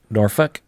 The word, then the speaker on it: Norfolk: Is it pronounced Norfolk? Norfolk? Not Norfolk? – Norfolk.